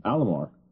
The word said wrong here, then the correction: Amalur: It's Amalur, dude. – Amalur? – A-M-A-L-U-R. Yeah, that's how it's spelled. – Amalur. Oh. – No, not Alamur.